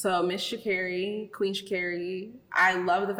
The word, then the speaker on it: Sha'Carri: I will say, just because I had to watch her video a few times mm-hmm. to make sure I say it properly, it's Sha'Carri. It's Sha'Carri.